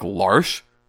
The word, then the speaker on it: Lars: Lars. Everybody pronounces it Lars, but where that character is from, that spelling of that name would be pronounced like Larsh.